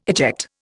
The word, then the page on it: eject: Indian